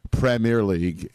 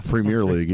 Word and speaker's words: Premier League: When he goes Premier League, Premier League, he goes the Premier League. When you put the emphasis, the accent on the pre, that means you're a soccer snob.